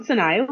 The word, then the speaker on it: Sonaya: Would you like to introduce yourself and make sure that I've got it right, please? – Sure thing. So I'm Sonia Williams.